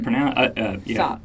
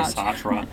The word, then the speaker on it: Sartre: It's Sartre. You're pronouncing it correctly. It's spelled S-A-R-T-R-E. Yeah, so lots of people say Sartre.